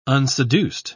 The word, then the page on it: unseduced: British English